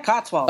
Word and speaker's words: Cotswold: Yeah, Cotswold.